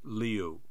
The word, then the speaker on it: Liu: She confirmed it's pronounced Liu, but she said, "I'm good with whatever."